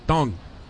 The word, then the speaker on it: Teng: It's spelled T-E-N-G, but because of the Taiwanese pronunciation, it's like D-H-U-N-G, Tong.